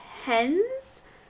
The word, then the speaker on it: Hennes: I thought you meant hen, like, Hens. Yeah, no, it's spelled H-E-N-N-E-S. – Okay. – Hens. I don't know how else you would say it. Hennes.